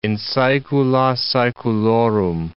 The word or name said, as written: in saecula saeculorum